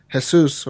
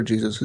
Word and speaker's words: Jesus: So I pronounce it in English. I could say it in Spanish, I guess. Jesus or whatever, but I prefer Jesus. It's easier to say, in my opinion.